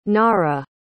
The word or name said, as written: naarah